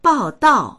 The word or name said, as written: bao4dao4